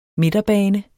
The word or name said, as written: midterbane